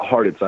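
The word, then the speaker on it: Hartitz: It's like Cheez-Its, but Harditz, I always tell people.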